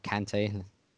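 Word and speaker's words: Kanté: Kante. – Yeah. – Or Kante. Sorry, try to pronounce it French.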